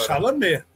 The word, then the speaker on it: Chalamet: É, eu acho que o Timothée Chalamet, sei lá como é que fala o nome dele. – Chalamet. Caralho, fudeu agora. – Chalamet.